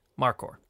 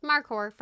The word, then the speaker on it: markhor: Markhor. – Markhor from Pakistan.